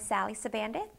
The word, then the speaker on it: Sally Sabandith: My name is Sally Sabandith. It's spelled S-A-L-L-Y, last name S-A-B-A-N-D-I-T-H.